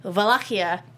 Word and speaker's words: Wallachia: Somebody directing them should have like said something, and it's it's basically in in English it's Wallachia, but it's actually Wallachia in Romanian.